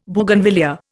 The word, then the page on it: Bougainvillaea: Indian